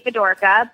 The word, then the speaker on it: Fedorka: My email is my first and last name, Carleigh Fedorka, but it's spelled kind of funny.